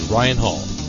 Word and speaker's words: Ryan Hull: Install the software and call me, Ryan Hall, that's R-Y-A-N-H-U-L-L, all one word.